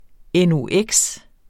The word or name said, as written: NOx